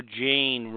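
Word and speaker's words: Jeyne: Well, will poor Jane rhymes with Payne Poole be around for a bit?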